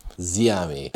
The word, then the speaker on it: Ziami: So in Spanish, the accent emphasizes where you're meant to emphasize the vowel. There's only so many syllables. The syllable, sorry, not the vowel. Ziami. Instead of Ziami or something like that, okay? – Ziami.